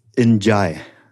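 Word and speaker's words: N'Diaye: So it's Njai. And this is something I should have known because in my previous incarnation, running a record company and did all sorts of West African music. And that is how the names are pronounced.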